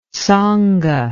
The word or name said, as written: tsonga